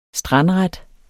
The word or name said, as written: strandret